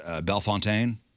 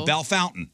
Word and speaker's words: Bellefontaine: Bell Fountain? – Yeah. – Like, we just took every French name and said, we refuse. – Like, it looks like it should be pronounced Bellefontaine. – Mm-hmm. Oh. – We say Bell Fountain.